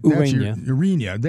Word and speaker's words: Ureña: Ureña, and I hope I'm pronouncing his name right. – Ureña.